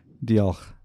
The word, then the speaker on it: Diolch: How do you say it? – Dioch. – Dioch. – D-I-O-L-C-H. – Dioch. Something like that? – Yeah. – Got it. – Back in the throat.